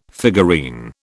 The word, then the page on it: figurine: British English